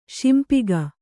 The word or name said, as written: ಶಿಂಪಿಗ